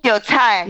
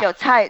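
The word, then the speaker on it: Jiucai (韭菜): Jiucai. – Yeah. – Jiucai.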